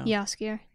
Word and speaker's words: Jaskier: We haven't talked about Jaskier. How do you say his name? I know Jaskier.